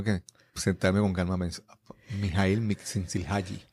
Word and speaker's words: Csikszentmihalyi: Eh, así es que él impulsa ese movimiento junto a Mihaly Csikszentmihalyi y otros colaboradores.